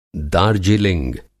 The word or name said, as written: दार्जिलिंग